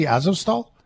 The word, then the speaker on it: Azovstal: It's the thing, Mariupol and the Azovstal. How do I say it properly? Azovstal? – Yes, you're saying it Okay. – correctly.